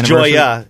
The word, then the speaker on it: Joia: It's Joya. It's spelled J O I A.